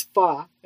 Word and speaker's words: pho: You took me to this Vietnamese place, which is so funny because remember I called it pho and you were like, it's pho. And I'm like, no, it's not. It's pho. It's spelled P-H-O. And you're like, it's pronounced pho.